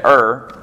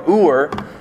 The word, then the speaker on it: Ur: Some people say Ur. Um, that's probably a southern way of saying it, but, but it's probably more Ur, uh, is how we, you really want to pronounce that.